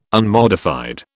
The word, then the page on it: unmodified: British English